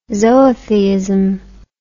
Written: zootheism

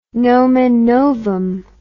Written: nomen novum

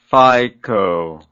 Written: phyco

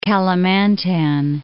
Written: Kalimantan

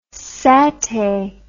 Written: satai

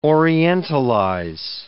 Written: orientalize

